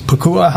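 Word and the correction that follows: Nacua: How do you say the rookies? – Nakua. – Nakua. – Yeah, Puka Nakua.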